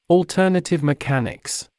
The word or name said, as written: alternative mechanics